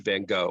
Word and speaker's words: van Gogh: But that brings to mind the story of Vincent van Gogh, or Van Gogh, depending upon how you pronounce it.